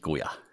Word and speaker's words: Goya: wo es jetzt Folgefinanzierung gab Alex Goya. Goya, habe ich es richtig ausgesprochen diesmal? – Genau, richtig, Goya.